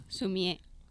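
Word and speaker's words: sumi-e: Le encanta pintar con tinta china aguada al estilo japonés, lo que se llama sumi. ¿Lo he dicho bien? – Sumie. – Sumie.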